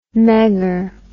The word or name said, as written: megger